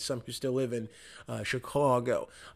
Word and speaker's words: Chicago: They find it in Chicago, or they, and that's how they say it over there, by the way. It's a long O. Chicago is what,